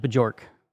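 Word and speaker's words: Björk: It's actually pronounced Bajork. That was one of the mistakes. So Bajork? – Yeah. – Bajork. – Bajork.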